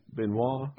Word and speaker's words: Benoit: Uh, is it Benoit Blanc? Is that how they say it? Benoit. Oh. Benoit Blanc. Ben, Benoit Blanc.